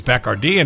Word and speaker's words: Bacardi: And I got the whole tour and the history, and that's how I learned that, you know, it was actually pronounced Bacardi, but like you said, they don't mind if you say Bacardi, but it was Bacardi.